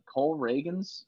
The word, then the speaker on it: Ragans: Cole Ragans, I think that's how you say his name.